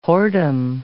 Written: whoredom

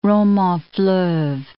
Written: romans-fleuves

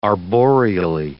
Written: arboreally

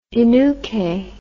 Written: innuce

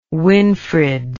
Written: winfred

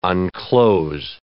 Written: unclose

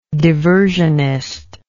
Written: diversionism

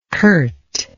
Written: curtness

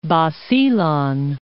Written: Basilan

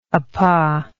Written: APA

